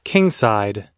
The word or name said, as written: kingside